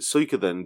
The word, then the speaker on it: Suikoden: I've always pronounced it Suikoden because there's the Japanese word Suika, which is spelled in English, it would be spelled Suika. So I've always pronounced it Suikoden based on that.